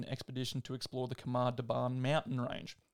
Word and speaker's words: Khamar-Daban: I don't even know that's how you pronounce it. Might be Daban, Khamar-Daban range. – The way you said it's perfect.